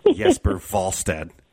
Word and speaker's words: Jesper Wallstedt: By the way, fantastic pronunciation, the Swedish pronunciation of Jesper Wallstedt.